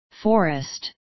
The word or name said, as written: forest